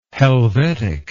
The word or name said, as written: helvetic